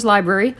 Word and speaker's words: LBRY: You can know when I upload stuff to my library, or LBRY, but it is pronounced as library.